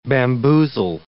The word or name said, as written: bamboozle